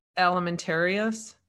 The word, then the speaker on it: Alimentarius: Let's see, at the bottom here, we got develop international norms, standards, and recommendations through the Codex. I'm going to butcher this. Alimentarius. – There you go. You got it. – Am I saying it right? – Yeah.